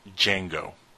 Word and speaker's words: Django: It's the D is silent, by the way. It's Django. – Sure. – Not the Django.